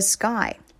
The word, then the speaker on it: sky: 接下来 The sky 这个词呢，也是一个很饱满的这个发音。 K 放在 S 后面，它发了一个 G 的音。 Sky Y 呢，在这里是这个双元音 I Sky 整个的这个 I 的发音要靠后一些。